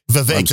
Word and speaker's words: Vivek: Vivek, that's right. Cake. Rhymes with cake.